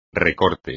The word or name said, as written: recorte